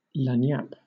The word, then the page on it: lagniappe: British English